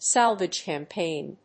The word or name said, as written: salvage campaign